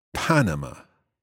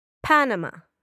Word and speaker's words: Panama: Ok, cuidado. En inglés, Panama. – Panama. – Exacto. Entonces, el estrés es en la primera sílaba.